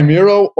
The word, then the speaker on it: Miro: I go by Miro, or Miro to all my Spanish friends.